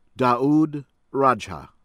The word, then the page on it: Daoud Rajha: American English